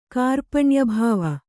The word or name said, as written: ಕಾರ್ಪಣ್ಯಭಾವ